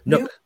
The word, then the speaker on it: Nuk: Now, how do you pronounce the N-U-K name? Is it Nuke? Nook. Nook.